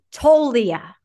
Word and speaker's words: Toglia: The most important thing I want to note here is the pronunciation of Michael Tolia. The G is silent.